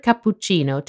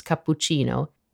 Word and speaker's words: cappuccino: To be completely honest, the way English speakers pronounce cappuccino isn't so different from the authentic Italian pronunciation. The biggest difference is that English people fail to properly pronounce the double consonants. In the word cappuccino, it just so happens that there are two, the PP and the CC.